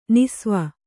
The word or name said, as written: ನಿಸ್ವ